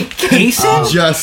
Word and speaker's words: Kaysen: They can't say Kaysen? It's Jason with the K.